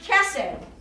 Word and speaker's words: chesed: Now sometimes when they write it, they leave off this C and leave off this H and just spell it H-E-S-E-D with a dot under those two because of the kind of Hebrew letters they are. Chesed, C-H-E-S-E-D-H.